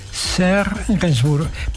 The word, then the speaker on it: Serge Gainsbourg: Escrito por Serge Gainsbourg. ¿Lo he pronunciado bien? Serge Gainsbourg, perfecto.